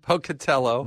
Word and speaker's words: Pocatello: But I'm flying out of Pocatillo. Yeah, Polka what? – Yeah, Pocatillo. Did I say it wrong? – Pocatello.